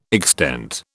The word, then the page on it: extent: British English